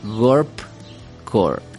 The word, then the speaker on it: Gorpcore: Eh, has interactuado más con este tipo de moda y bueno, el programa de hoy tiene que ver con el Gorp Core.